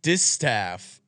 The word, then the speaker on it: Distaff: Moving over to the Breeders' Cup Distaff. I'm most certainly pronouncing that wrong. Saturday. – No, you got it.